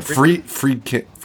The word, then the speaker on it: Friedkin: I wonder what William Fredrickson, Fred, how do you say his name? – William Friedkin.